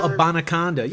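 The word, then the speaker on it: Abanikanda: We're going to give, oh God, I'm going to butcher his last name, Israel Abanaconda. – Israel Abanaconda. Yeah, you got it.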